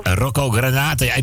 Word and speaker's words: Granata: Granata, hoe spreek je dat uit? Granata? – Ja, Rocco Granata.